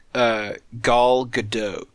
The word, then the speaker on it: Gal Gadot: I think it's Gal like doll and then dough like bread dough, like dote.